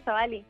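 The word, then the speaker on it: Tzuali: Sí, Suali. – ¿De dónde es tu nombre? Es de Michoacán, es Purepecha. – ¡Qué buena onda, Chuali! Pero se escribe con T al principio, ¿no? Sí, TZ.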